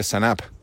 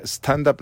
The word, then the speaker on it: stand-up: Es como lo que decían cuando empezamos a hacer stand-up hace muchísimos años. Mucha gente decía Sanap. Sanap. Decía, ¿qué es el Sanap? Claro, stand-up no le salía, decía Sanap.